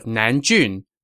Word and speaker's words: Nanjun: It's the city of Nanjun, not Nanzheng, as I had pronounced it.